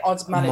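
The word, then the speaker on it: Osmanagić: So this is Sam Osmanagic, is that right? Is that how I pronounce it? – Yeah, Dr. Samir Osmanagic.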